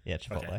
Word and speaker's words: Chipotle: Did I say it right also? Chipotle, right? – Yeah, Chipotle.